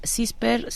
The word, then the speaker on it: SISPER: CISPER se, se escribe S-I-S-P. e R son las siglas de Sistema de Información sobre Peligros y Riesgos.